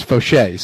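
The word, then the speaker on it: Faucheux: Yesterday, Ron Fauché had to whack me after I gave him a French Canadian pronunciation of Fauché, which, you know, if he was training at Hippodrome Blue Bonnets, it'd be Fauché. But down there in Acadiana, they it's Fauché. So he corrected me.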